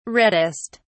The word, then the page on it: reddest: British English